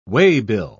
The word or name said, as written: waybill